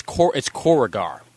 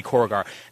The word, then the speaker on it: Korugar: Actually, it's Korrigar. That is the actual pronunciation of the homeworld, because I remember them actually pronouncing it like that on JLU, if I remember correctly. So it's, it would be Korugar.